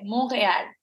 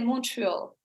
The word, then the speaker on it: Montreal: The French Quebecois way, Montreal. – Yeah. – The English, maybe, way will say Montreal.